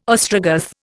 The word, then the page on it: Ostrogoth: Indian